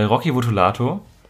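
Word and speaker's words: Rocky Votolato: Damals mit ähm, Casper, ähm, Prinz P, äh, Slime und äh, Rocky Votulato. Ja, so spricht man den aus.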